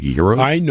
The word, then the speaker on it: gyros: You call it gyro. It would be pronounced gyros.